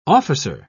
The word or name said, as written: officer